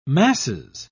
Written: masses

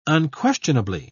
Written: unquestionably

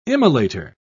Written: immolator

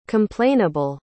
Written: complainable

